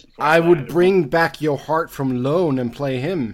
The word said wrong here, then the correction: Joe Hart: Whoa, I mean, when did Joe Hart get a soft J in his name?